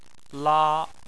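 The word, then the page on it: la: tone 1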